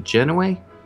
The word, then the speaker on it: Genoway: So anyway, this is by Sam Genoway, and unfortunately, I always feel like I'm saying his name wrong. Is it Genoway? – Genoway. – It is Genoway.